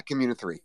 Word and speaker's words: Communi3: Yeah, Communi3.